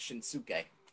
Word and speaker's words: Shinsuke: Everybody says it's Shinsuke, but it's not Shinsuke. It's Shinsuke.